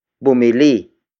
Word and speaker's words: bumili: The next word is bumili. Bumili. Bumili. Bumili is spelled as B-U-M-I-L-I.